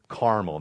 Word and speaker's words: Carmel: And there was a man in Maon whose business was in Carmel. That's how we're going to pronounce it today, but I might slip and say Carmel because, you know, that's where we live.